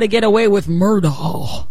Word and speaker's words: Murdaugh: And it's really How to Get Away with Murdaugh, if you go by their own pronunciation.